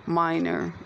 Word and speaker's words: Miner: I am available to be messaged there at Chuki, initial G, initial L, minor, M-I-N-E-R, and the first name Chuki is spelled T-C-H-O-U-K-I.